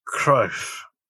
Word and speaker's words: Cruyff: And Janma says it's pronounced Cruyff.